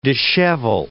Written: dishevel

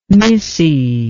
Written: misce

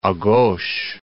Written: a gauche

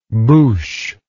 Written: bouche

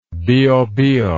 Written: biobio

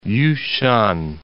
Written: Yu Shan

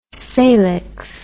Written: salix

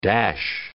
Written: dash

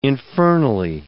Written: infernally

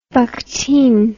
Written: bakhtin

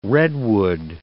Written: redwood

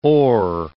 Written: oar

